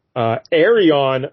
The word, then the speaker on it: Arion: Arion, Lord of Atlantis. And that is spelled A-R-I-O-N, Arion.